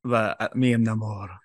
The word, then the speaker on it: Namor: I'm just gonna call him Namor for now, because that's how I've heard it pronounced. Namor.